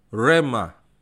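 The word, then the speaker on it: Rema (ῥῆμα): Firman di situ kalau sudah melihat saya ada kasih garis merah rema ya. Bacanya yang sebelah kanan. Rema.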